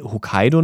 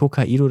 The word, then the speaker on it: Hokkaido: Aber es heißt Hokkaido und nicht Hokkaido, das wird immer falsch ausgesprochen.